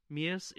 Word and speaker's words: Mias: And the way you pronounce Mias is like M-E-U-S. And so I thought, oh, me us, that'll be easy for people to remember how to pronounce my name.